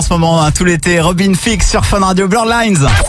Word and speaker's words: Robin Thicke: Robin Thicke. – Robin Thicke. – Robin Thicke.